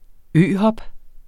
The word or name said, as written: øhop